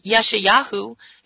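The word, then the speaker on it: Yeshayahu (ישעיהו): You know, in Isaiah chapter 40, we have Isaiah the prophet, Yeshayahu, is how you say Isaiah in Hebrew.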